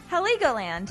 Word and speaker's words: Heligoland: We got new releases from Massive Attack with Heligo Island. Why not? You say it. – Heligoland. – Heligoland. Is that how it's said? Yeah. Heligoland. That makes more sense if you know Legoland.